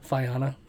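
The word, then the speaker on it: Fiyana: It's F-I-Y-A-N-A. And N as in Nancy. – How did you pronounce it? Fiana? – Fiana. That's how I figured.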